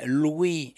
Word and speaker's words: Louis: Louis Jordan, d'ailleurs je signale que je dis bien Louis et non pas Louis Jordan, parce que Louis Jordan voulait qu'on prononce Louis comme Louis Armstrong. Voilà, sinon on aurait pu prononcer Louis.